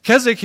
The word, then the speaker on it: Keswick: It's spelled Keswick, but it's pronounced Keswick.